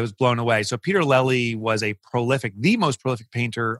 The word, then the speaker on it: Lely: how do you say his last name? – Lely. – Yeah.